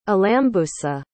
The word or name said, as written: alambusa